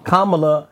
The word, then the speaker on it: Kamala: I said to Rick in this conversation that Kamala... I said her name right, correct? Yeah, Kamala has been second in command for 4 years.